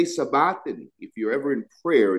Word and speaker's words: sabachthani: It says, in about the ninth hour, Jesus cried with a loud voice, saying, Eli, Eli, lama sabachthani, is actually how it's pronounced.